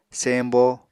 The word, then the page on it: sembo: Apatani